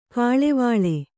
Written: ಕಳವಳ

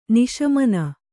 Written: ನಿಶಮನ

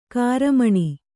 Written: ಗ್ರಾಮೀಣ

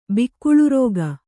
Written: ಬಿಕ್ಕುಳುರೋಗ

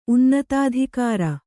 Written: ಉನ್ನತಾಧಿಕಾರ

